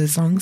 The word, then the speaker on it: Zhong: I'd love to know more about your your wellness hub that you run in St Kilda, the Zhong Centre if I'm pronouncing that correctly. – You pronounced it perfect.